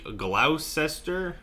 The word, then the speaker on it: Gloucester: But we're going to start off with a big shout-out to Deputy Jay Holt of the Gloucester... How would you say that? Right there, that G word. – Gloucester.